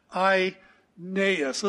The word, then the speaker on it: Aeneas: And so I did a little research and discovered here's the correct pronunciation, Aeneas. Let's just say that together, Aeneas.